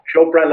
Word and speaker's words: Chopra: Chopra is the right pronunciation, isn't it? – Yeah, that's correct. – That's correct. Good. Okay. – Like Oprah. Chopra like Oprah. – Chopra like Oprah. Okie dokes.